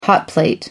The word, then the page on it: hotplate: American English